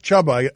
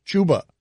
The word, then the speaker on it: Chuba: It's not Chuba. Evidently, it's Chuba.